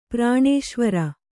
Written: ಪ್ರಾಣೇಶ್ವರಿ

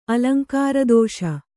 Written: ಅಲಂಕಾರದೋಷ

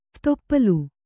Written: ಕಯ್ಮರೆ

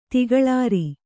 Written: ತಿಗಳಾರಿ

